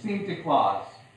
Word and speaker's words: Sinterklaas: See, that's how you pronounce it in Dutch, Sinterklaas.